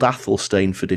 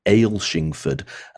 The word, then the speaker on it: Athelstaneford: And it's in a lovely little village called Athelstaneford, Well, it's called Athelstaneford if you pronounce it the English way. If you live in Scotland, people will pronounce it differently. And they'll pronounce it as Aylshingford. And you'll look at the spelling, you'll think, how do you get Aylshingford from that?